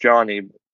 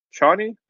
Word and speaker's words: Chani: We have got Dune Part 2, starring Timothée Chalamet as Paul Atreides and Daya as, it's pronounced Johnny. Chani or Johnny? – I heard him saying Shawnee or Chani. – I heard Shawnee, but it just seemed like in the first one, I think it's pronounced like Shawnee, but I think in the second one, I felt like they kept saying Johnny, even though, whatever, I don't even care.